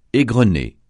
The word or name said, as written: égrener